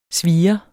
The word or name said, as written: svir